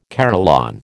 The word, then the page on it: carillon: American English